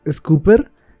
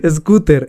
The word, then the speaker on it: scooter: Ah, hoy me corrigieron y me dijeron que no es una Scooper, sino una Scoober. No me cómo se pronuncia. scooter, scooter, se pronuncia scooter y que se parece más a otra moto, weón, yo no sé de motos, no me interesa.